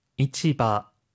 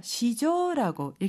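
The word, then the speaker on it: 이치바 (市場): 이치바, 이치바. – 이치바, 시장인데요. 한자로 시장이라고 써요. 그런데 이 한자어는요. 지금처럼 이치바로도 읽히고요. 음으로 읽어서 시조라고 읽히기도 해요.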